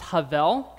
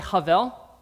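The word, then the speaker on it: Havel (הבל): In Hebrew, Abel is pronounced Havel. Can I hear you all say Havel?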